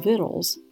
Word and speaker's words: victuals: Her latest book, called Vittles, is spelled V-I-C-T-U-A-L-S, and it's recently won two James Beard Awards, one for Cookbook of the Year and one for Publication of the Year.